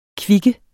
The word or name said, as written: kvik